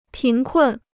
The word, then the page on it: 贫困: pín kùn